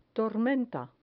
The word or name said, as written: tormenta